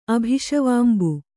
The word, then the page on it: ಅಭಿಷವಾಂಬು: kannada